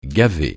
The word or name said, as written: gaver